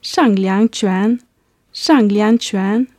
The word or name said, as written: shanglianquan